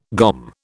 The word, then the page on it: GOM: British English